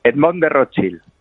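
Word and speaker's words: Rothschild: No sé si lo he pronunciado bien. ¿cómo estás? Muy bienvenido a Tu Dinero Nunca Duerme. – Muchas gracias. Y hola a todos. – ¿Lo he dicho bien? Edmond de Rochefield. – Edmond de Rochefield. – Edmond de Rochefield, perfecto.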